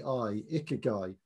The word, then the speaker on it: Ikigai: Ikigai. – Presumably where you learn how to say it. – Correct. There is a section in there. I couldn't write it in the Japanese mnemonics, but it's I-K-I-G-A-I, Ikigai.